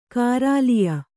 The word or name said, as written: ಕಾರಾಲಿಯ